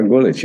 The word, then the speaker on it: Gulič: Rok Gulič, yes.